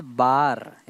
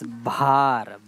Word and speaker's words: भार: It's not bar, it's bhar.